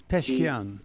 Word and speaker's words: Pezeshkian: It's Pezeshkian.